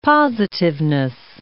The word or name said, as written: positiveness